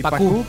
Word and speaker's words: Pacú: Pacú con acento en la U. – Correcto. Si no, diría Paco.